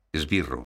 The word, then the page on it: esbirro: galega